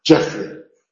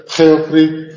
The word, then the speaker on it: Geoffrey: Entonces, se pronuncia Jeffrey. No se pronuncia Geoffrey, ni Geoffrey, ni nada por el estilo. Es Jeffrey.